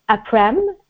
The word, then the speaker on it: Après: And you can pronounce it in English as Après.